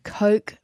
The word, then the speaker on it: Koch: Strike Energy Managing Director Stuart Nichols speaking to Lucinda Jose about this morning's announcement that the massive global company Coke Fertiliser, which is spelt K-O-C-H, Coke Fertiliser, is the preferred bidder to take all of the urea Strike hopes to produce at its proposed plant in Geraldton.